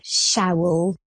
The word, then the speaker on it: Showell: Like, I would say Showell, but that's not quite it, is it? – I've heard all sorts, Lizzie, don't worry. It's Eliza Showell.